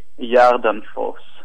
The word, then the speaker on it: Gardenfors: If you speak English, you can say Gardenfors. But in uh, Sweden, I say Jardenfors.